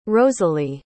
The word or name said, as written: rosalee